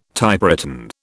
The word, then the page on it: Typewritten: British English